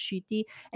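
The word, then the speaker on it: Ncuti: But like Shruti Gatwa, it's spelled Nikuti, but it's pronounced Shruti.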